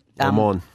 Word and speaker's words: Le Mans: Some bits are overblown, like a certain thing happens at the start of the Le Mans. Is that how you pronounce it? – Le Mans.